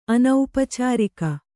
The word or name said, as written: ಅನೌಪಚಾರಿಕ